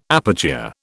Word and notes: aperture: British English

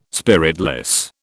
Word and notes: spiritless: British English

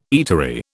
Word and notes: eatery: British English

